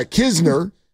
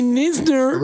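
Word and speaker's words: Knizner: No, but his, he's got a KN. – That's right. – It's like Carl Kisner. – It's a silent N. – Right. Carl Kisner, the catcher. – Usually when it's KN, you don't pronounce the K. But Kisner's like, watch this, people. – Waka waka. – You don't pronounce the N. – Don't call me Nisner.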